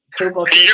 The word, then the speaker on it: Kowalkiewicz: I mean, a huge, huge, huge fight at UFC 201 against Karolina Kowalkiewicz. Did I say that right? – Kowalkiewicz. You're awesome.